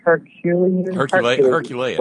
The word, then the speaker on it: Herculean: Am I saying it right? – Herculean, but, yes, Herculean.